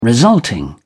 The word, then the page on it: resulting: British English